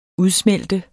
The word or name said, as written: udsmelte